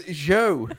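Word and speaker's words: Zhou: His name's not Zoo. It's Joe.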